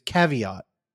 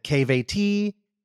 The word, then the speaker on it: Caveat: And the conversations that would happen between the two, who neither of them could pronounce the name, which is Caveat, would call it KVT, Cavette.